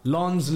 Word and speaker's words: Lens: I said Lens. – Lons, Lille.